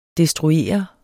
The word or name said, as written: destruere